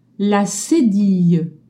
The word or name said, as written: cedille